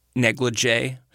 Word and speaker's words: NLGJA: Tinderbox also led to the National Lesbian and Gay Journalists Association, the acronym is pronounced NLGJA, naming me Journalist of the Year.